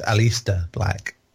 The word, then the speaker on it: Aleister Black: You got to say it right. It's Aleister Black.